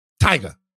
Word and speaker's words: Tyga: Say TYGA. – Tiger. – Tiger. Tiger. Am I saying it right? – Tiger. Say it again. – Tiger. Tiger. Yeah.